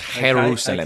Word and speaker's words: Jerusalem: It's actually pronounced Jerusalem.